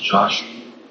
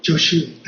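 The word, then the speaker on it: Jōshū: I think, it's pronounced Joshu. – Joshu. That's a Japanese pronunciation of Jojo's name, yeah.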